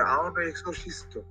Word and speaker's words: 青のエクソシスト: あの、最初はこれ、青のエクソシストって読むんですよ。